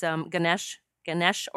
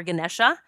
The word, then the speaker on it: Ganesha: Someone I call on every morning is Ganesh or Ganesha. People pronounce it either way.